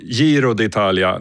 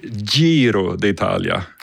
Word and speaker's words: Giro d'Italia: sa Giro d'Italia när det heter Giro d'Italia. Alltså med ett litet hårt sånt DG-uttal.